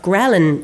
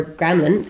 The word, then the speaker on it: ghrelin: Ghrelin, or gremlin, as you like to pronounce it, is not the most well-known one.